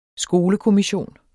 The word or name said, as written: skolekommission